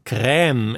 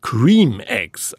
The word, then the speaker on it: Creme: Nachdem die Herstellerfirma Cadbury in einem Tweet die französisch anmutende Aussprache Crème Eggs propagierte, brach ein Shitstorm los, der das Unternehmen bald darauf veranlasste, den ersten Tweet zu löschen und umgehend zu korrigieren, dass die Eier selbstverständlich Cream Eggs ausgesprochen würden.